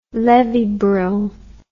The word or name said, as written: levy bruhl